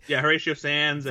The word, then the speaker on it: Horatio Sanz: Horatio Sanz and Brian Posehn was in that first episode.